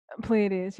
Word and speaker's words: Pleiades: But I know you've been doing work around, or you've been studying some rituals around the Pleiades. Is that, am I saying that correctly? – Pleiades, yeah.